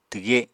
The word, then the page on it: twge: apatani